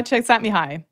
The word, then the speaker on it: Csikszentmihalyi: Oh, Mikhail Csikszentmihalyi.